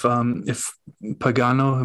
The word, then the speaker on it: Pagano: If Pagano, am I saying that right? – Yes.